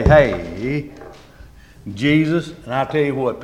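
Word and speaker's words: Jesús: They pronounce it Jesus. – Jesus, but it's spelt the same.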